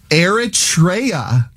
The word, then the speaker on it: Eritrea: It's called Eritrea, and that is the correct pronunciation.